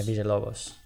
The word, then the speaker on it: Villalobos: Yeah, and fucking Vill, how do you say it? Villalobos? – Yeah, Villalobos.